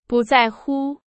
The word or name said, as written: 不在乎